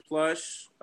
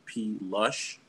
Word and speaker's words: P. Lush: You could find me at Ken Plush. So my name is Ken Pettis, and it's supposed to be Ken P. Lush, like loves to drink. But everybody literally just sounds it out phonetically as Plush.